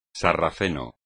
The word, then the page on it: sarraceno: espagnol